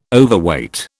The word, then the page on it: Overweight: British English